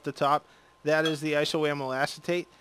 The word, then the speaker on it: isoamyl acetate: It is isoamyl acetate.